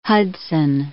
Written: Hudson

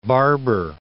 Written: Barbour

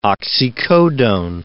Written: oxycodone

